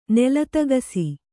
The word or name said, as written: ನೆಲತಗಸಿ